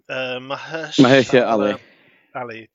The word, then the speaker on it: Mahershala Ali: Mahershala Ali from Green Book.